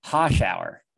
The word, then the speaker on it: Hoshauer: So our first caller on the show is none other than Ryan's girlfriend, Jen Hoshauer. That's how you say your last name. I just want to make sure it's correct for everybody listening, right? – Yep. – Hoshauer.